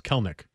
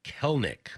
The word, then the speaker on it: Kelenic: This text, by the way, says it's two syllables, Kelenic. – Kelenic.